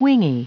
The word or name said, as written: wingy